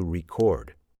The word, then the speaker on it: record: Now, to record, think about the way we pronounce that word and where the emphasis is on the syllable. It's on the second syllable, to record.